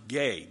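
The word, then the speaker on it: gei (γῆ): Now, when he says the earth, he uses the Greek word, we would pronounce it gei, because that's the word that represented the planet itself.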